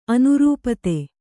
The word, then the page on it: ಅನರ್ಪಿತ: anarpita